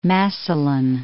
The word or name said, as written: Massillon